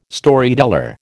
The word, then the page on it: Storyteller: American English